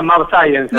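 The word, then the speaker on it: mAbxience: Sí, se pronuncia Map Science en realidad Science, porque es una mezcla de Map, que es anticuerpo monoclonal, y Science por la ciencia.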